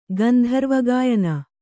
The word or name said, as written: ಗಂಧರ್ವಗಾಯನ